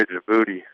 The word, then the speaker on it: Djibouti: I would actually have to say Djibouti, Africa. – Djibouti? – Yeah. – Okay. How do you spell that? – It's a D-J-I. – D-J-I. – B-O-U-T-I. – B-O-U-T-I.